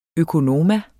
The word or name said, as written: økonoma